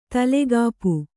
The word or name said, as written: ತಲೆಗಾಪು